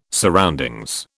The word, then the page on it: surroundings: British English